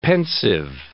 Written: pensive

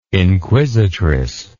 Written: inquisitress

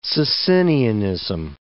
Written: Socinianism

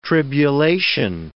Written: tribulation

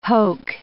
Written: hoke

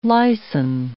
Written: lysin

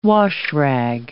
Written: washrag

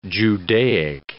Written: Judaic

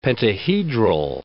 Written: pentahedral

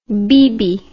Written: beebee

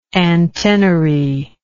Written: antennary

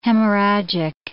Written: hemorrhagic

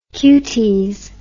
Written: cutes

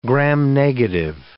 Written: gram-negative

